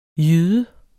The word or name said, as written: jyde